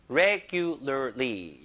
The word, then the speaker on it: regularly: L 발음, R 발음이 한국 사람 다 어려운데. 이게 지금 세 개가 연달아 나와가지고 여기까지 하면 네 개네요. regularly. 이렇게 좀 끊어보세요. regularly. 이걸 좀 붙여야 돼요. regularly.